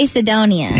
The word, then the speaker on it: Cedonia: And for some reason, you know, hearing them through the phone, like, how do you say this word? C-E-D-O-N-I-A. – C-E-D-O-N-I-A? – Yeah. Is that Sedonia? – I would say Sedonia. – Yeah, I think that sounds right. – Sidonia. – How do you say B-A-R-E-A? – B-A-R-E-A.